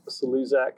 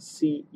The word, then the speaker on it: Celuszak: So we're at Crowd Emotion or at Matt Celuszak. I'll spell that Celuszak.